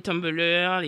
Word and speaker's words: Tumblr: Et puis donc euh, les Black Twitter, les Tumblr, etc., etc. Je prononce très mal Tom, je sais pas comment on dit Tom. – Tumblr. Tumblr. Tumblr.